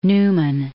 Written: Newman

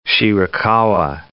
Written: Shirakawa

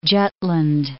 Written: Jutland

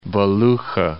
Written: Belukha

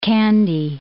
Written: Kandy

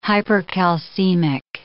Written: hypercalcemic

